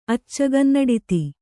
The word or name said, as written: ಅಚ್ಚಗನ್ನಡಿತಿ